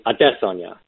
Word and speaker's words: Adesanya: Yes, and plus one for pronouncing his name correctly. – Of course. All weekend long, it will be Adesanya.